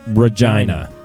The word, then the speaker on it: Regina: We live in a city that is spelled like Regina. But apparently the queen at the time pronounced it Regina, which is incredibly unfortunate for Well, if you say it fast.